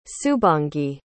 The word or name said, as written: subhangi